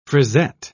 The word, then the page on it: frisette: British English